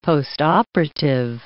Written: postoperative

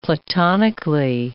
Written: platonically

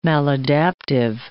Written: maladaptive

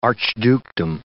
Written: archdukedom

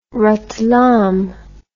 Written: ratlam

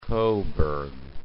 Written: Coburg

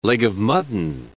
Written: leg-o'-mutton

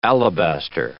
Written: alabaster

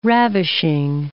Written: ravishing

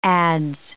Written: adz

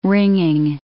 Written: ringing

